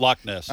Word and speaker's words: Loch Ness: What's the right way to say it? – Loch Ness. – Loch Ness Monster. – Loch means lake. – Yeah, in Scotland, they always have that kind of hard ending to words. – Right. Loch Ness. – But it is L-O-C-H.